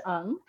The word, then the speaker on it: Ng: my last name is Williams hyphen NG, and it's because my Asian husband has an unusual name, but it's pronounced Ung.